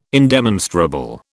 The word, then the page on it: indemonstrable: British English